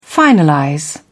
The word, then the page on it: finalize: British English